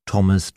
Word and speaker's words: Thomas: Außerdem muss man die Ausnahmen kennen. Als denglische Patienten brauchen wir uns nämlich gar nicht mit der Aussprache von TH abzumühen, wenn es ganz einfach wie ein T gesprochen wird, in Namen wie Theresa, Thomas, Thompson, Thames, Thai, Lesotho oder Thyme, dem Gewürz Thymian.